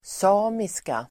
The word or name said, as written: samiska